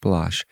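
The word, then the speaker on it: Baloche: And this song by Paul Balazs, yes, his last name is pronounced Balazs, is called Open the Eyes of My Heart.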